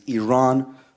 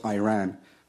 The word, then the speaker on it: Iran: First of all, you say you support the Iranian people, but you're pronouncing our country's name wrong. Our country is named Iran, not Iran.